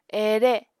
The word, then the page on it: ere: Apatani